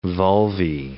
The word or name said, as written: vulvae